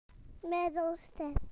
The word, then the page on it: middle: British English